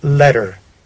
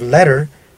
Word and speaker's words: letter: In the case of letter, they do not pronounce the E that comes before the R. It is as though that E did not exist.